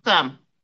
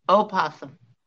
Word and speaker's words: opossum: I haven't seen possums in a while, but we had possums. Now we got rabbits. – Opossum. – Huh? – Opossum. – Opossum. – I always say it wrong.